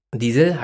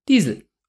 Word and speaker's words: Diesel: Das war die Idee, man wollte einen internationalen Namen haben, der in allen Ländern gleich ausgesprochen wird. Diesel heißt einfach überall Diesel.